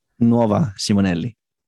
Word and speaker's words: Nuova Simonelli: Did I say that right? Novelli. How do you say it? It's an Italian. I can't do Italian. – Nuova Simonelli. – That's what I said, isn't it? – Not nearly.